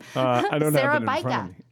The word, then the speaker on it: Sarabajka: How do you say his last name? – Sarabajka. – Yes, Sarabajka. Yeah.